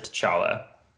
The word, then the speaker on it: T'Challa: I always pronounce it T'Challa.